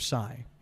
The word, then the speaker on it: Tsai: But if this guy, Joseph Tsai, I hope I'm saying his name right.